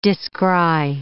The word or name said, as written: descry